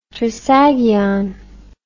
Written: trisagion

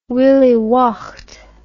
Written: willie waught

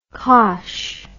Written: kosh

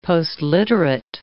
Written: postliterate

